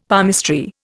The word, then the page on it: palmistry: Indian